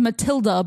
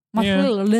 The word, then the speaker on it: Mathilde: Luckily, he ended up with, I'm going to say her name is Matilda, but it's spelled M-A-T-H-I-L-D-E. – Matilda. Yeah, sure.